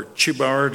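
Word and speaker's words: Chebar: He's with the captives by the river Kibar, or Chibar, depending on which pronunciation you believe is correct.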